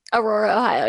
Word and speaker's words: Aurora, Ohio: Did I pronounce that right? – Aurora, Ohio. Yeah.